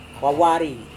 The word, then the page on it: wawari: Serewen